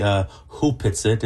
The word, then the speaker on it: Hupitsit: It's written Opitsit, but it's Opitsit. Actually pronounced properly Hupitsit.